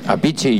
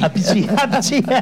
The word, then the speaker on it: Appice: Appice, yo vi una vez un documental. – Appice. – Sí, y lo pronunciaban, lo vi subtitulado. – Sí, y yo tampoco sabía que se pronunciaba Appice. – Appice.